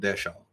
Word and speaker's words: DHL: But to get like a DHL package, I think they call it Deshaw, that's how they say it there.